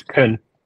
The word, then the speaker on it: Tun: It's Tun.